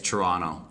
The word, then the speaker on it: Toronto: They don't pronounce the T. – Yeah. – It's Toronto.